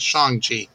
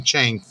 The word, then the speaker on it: Shang-Chi: And Marvel and Disney pretty much said, no, fuck y'all, y'all are all saying it wrong. It's Shang-Chi. Well, God, I hope they forgive us, because I think everyone was saying Chang all these years.